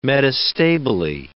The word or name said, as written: metastably